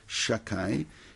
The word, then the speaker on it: Shaddai: Me'el avicha v'ezreka ve'et shad, I'm going to say the word Shaddai, or we pronounce it Shakai.